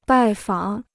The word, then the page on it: 拜访: bài fǎng